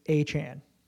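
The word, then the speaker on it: Achane: We got Troy up there going, Devon A-Chain. – He says it's A-Chan.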